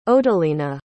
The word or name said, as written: odelyna